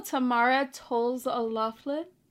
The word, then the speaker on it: Tamara Toles O'Laughlin: Well, welcome to the show, Tamara Toles O'Loughlin. Did I say it right? – Yeah, totally.